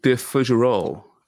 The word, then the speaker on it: de Fougerolle: Is that right? Fougerolle? I was mentioning that. – De Fougerolle.